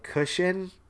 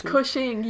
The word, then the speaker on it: Cushing: And I was like, Cushion? And they're like, yeah, Peter Cushing. Cushion. – Cushion. It's Cushing. You probably spell it like a cushion.